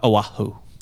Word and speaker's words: Oahu: uh, Oahu.